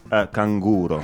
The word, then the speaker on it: canguro: In Italian, we have a very special word for kangaroo. It's canguro. – Kanguro. – Yes, it's kanguro.